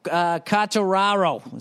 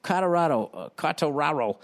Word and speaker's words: Quartararo: Congratulations to Fabio Quartararo. Is that how you say it? Quartararo.